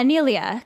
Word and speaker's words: Nelia: Nelia. Yeah, Nelia. Because it's like Neil and then Ia.